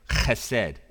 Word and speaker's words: Chesed: I'm not going to say it like a good Jew. I can't, Israelite Hebrew, I can't say it. Chesed is the right way for putting that.